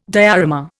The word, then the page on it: diorama: Indian